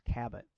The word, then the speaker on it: Cabbot: The main character, I want to say it's pronounced Cabot. C-A-B-B-O-T. – Yeah, that sounds right.